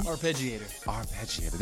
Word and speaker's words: arpeggiator: Arpeggiator? How do you say it? – Arpeggiator. – There you go.